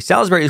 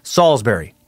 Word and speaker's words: Salisbury: Salisbury. Okay, I, I said Salisbury. Salisbury is pronounced Salisbury, not Salisbury.